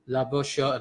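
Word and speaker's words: Labouchere: Let's see how it sounds like. Right. Laboucher. – Yeah. – Okay. Sure. Sounds really Frenchy.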